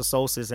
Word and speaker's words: solstice: Right now, we're out of winter solace, and I don't like solstice. That's how you say it winter solstice, and I pretty much don't like the winter time.